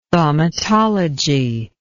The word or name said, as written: thaumatology